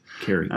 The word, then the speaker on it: Keri: Is that how you say that? – That's how I would pronounce it. – Oh, yes. – Very good. So I'm learning. – K-E-R-I. – Correct. – Kerry.